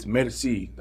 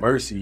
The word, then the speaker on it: Merci: It's French for thank you. – Okay. – So it's Merci. That's the correct pronunciation. We just Americanized it because it sounds a little cooler, a little grungier, a little more edgy.